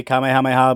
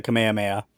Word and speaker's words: Kamehameha: They say Kamehameha, but it's the Kamehameha. – Is it the Kamehameha? Okay. – Yeah, after King Kamehameha.